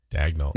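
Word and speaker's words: Daigneault: Is it Mark Daniel? Mark, like Mark Daniel? – Dagnall. – No, Dagnall.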